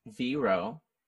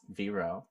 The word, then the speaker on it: VRO: It's Vero, pronounced Vero. No period. So it's going to be V-R-O. and that's pronounced like J-Lo.